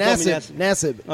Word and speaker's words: Nassib: If Nassib gets... And am I mispronouncing it again? – That's perfect. Nassib.